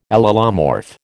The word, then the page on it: allelomorph: American English